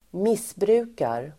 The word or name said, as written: missbrukar